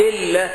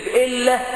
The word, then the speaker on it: الله: تسمى ربنا إلا، يقول لك الحج عبد الله، ويكتب إلا ألف لم ألف هاء على السطر، مش اسمه إلا، اسمه الله، اسمه الإله.